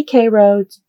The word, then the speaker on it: Cairo: Yes, I did say Cairo, despite the fact that it's spelled like Cairo, because that's how the locals say it.